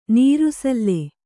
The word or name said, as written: ನಿರಸಲ